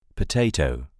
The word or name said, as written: potato